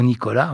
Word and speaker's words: Nicholas: Bon, Nicolas ou Nicolas, hein, on peut les prononcer à la française, c'est le nom français.